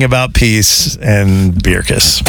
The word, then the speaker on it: Beerkus: It's definitely Bierkus. B-E-E-R-K-U-S. – Okay, I got it. – If that's not Bierkus, I don't know Bierkus.